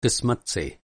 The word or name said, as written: क़िस्मत